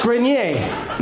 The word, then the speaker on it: Grenier: You call him Genier? – Do you fucking know I'm saying it wrong? – It's Grenier.